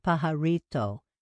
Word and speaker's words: Pajarito: She pronounced it Pajarito, trilling the R like the native Spanish speaker she was.